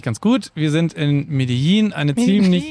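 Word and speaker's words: Medellín: Also wir sind gerade in Medellin. – Medellin. – Vielleicht machen wir lieber statt der fünf Fun Facts die richtige Aussprache. – Medellin.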